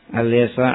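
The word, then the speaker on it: al-yasa (اليسع): Jadi yang benar dibacanya il-yasa atau al-yasa. Il-yasa atau al-yasa. Al-yasa kan? Sebagian il-yasa. Ada al-yasa. Al-yasa.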